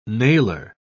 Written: nailer

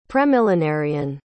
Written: premillenarian

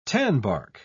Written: tanbark

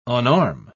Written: unarm

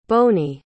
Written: boney

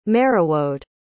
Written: merewode

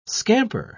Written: scamper

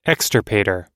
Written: extirpator

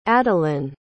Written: adalyn

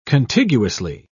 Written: contiguously

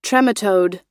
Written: trematode

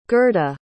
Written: gerda

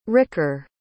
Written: ricker

